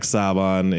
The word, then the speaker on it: Saban: Jack Saban, it's Jack Saban.